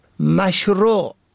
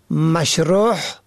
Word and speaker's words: مشروع: به گونه مثال مشروع که معنای آن آنچه مطابق شرع است را شماری مشروع یا مشروح به هی حلقی تلفظ می کنند که شرح شده معنا می دهد.